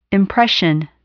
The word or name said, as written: impression